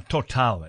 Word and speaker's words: Total: Now, anyway, Total finds, now Total actually is how we should be pronouncing it.